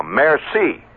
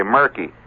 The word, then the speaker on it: merci: I, you know, just throw that in, or I'll say a merci. That's pretty good. I just learned last year that the C is pronounced that way. I used to say murky.